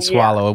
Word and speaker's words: Soileau: Well, my new last name will be Swallow, but it's spelled S-O-I-L-E-A-U. So it looks like Swole. – No way. Okay, so Megan Swallow.